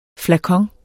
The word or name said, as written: flakon